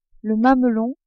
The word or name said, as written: mamelon